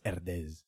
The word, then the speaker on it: Herdez: For the listeners, I know enough Spanish to know how that would be pronounced, Herdez.